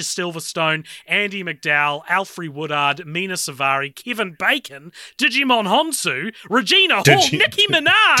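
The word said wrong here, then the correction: Djimon: Is that not how you say it? – No, it's Jaimon.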